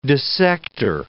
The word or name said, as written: dissector